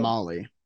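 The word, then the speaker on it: Mahle: Baseball Reference says phrase. Molly. That's all I'll say.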